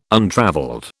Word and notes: untravelled: British English

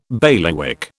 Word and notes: bailiwick: British English

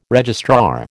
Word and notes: registrar: American English

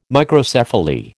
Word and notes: Microcephaly: American English

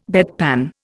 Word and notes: bedpan: Indian

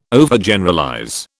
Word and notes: Overgeneralize: British English

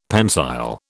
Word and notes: pensile: British English

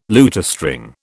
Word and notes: lutestring: British English

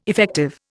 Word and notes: effective: Indian

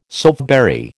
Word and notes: soapberry: American English

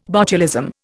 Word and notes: botulism: Indian

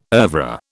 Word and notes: oeuvre: British English

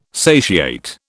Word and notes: satiate: British English

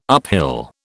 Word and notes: uphill: British English

